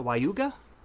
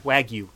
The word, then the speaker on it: Wagyu: Tim got, how do you say that, Wayuga? – Wagyu.